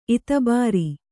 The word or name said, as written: ಇತುಬಾರು